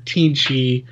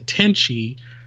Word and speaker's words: Teenchi: No, it's spelled T-E-E-N-C-H-I, which I have no idea where that name come from. I hear Teenchy, but my brain wants to correct it to Tenchy, and that's not good.